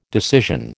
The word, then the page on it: decision: American English